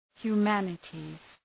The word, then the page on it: humanities: hju:’mænətız